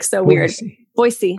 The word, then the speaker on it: Boise: And one thing that's funny is I've been corrected so many times on how I pronounce the city because I was saying Boise. And that's not how you say it. It's Boise with like a soft S and not as, doesn't that blow your mind?